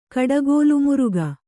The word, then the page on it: ಕಡಗೋಲುಮುರುಗ: kannada